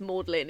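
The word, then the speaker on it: Magdalen: It's like, you know how Maudlin is Maudlin, even though it's named after Mary Magdalene. – Yeah, even though it's spelled Magdalene.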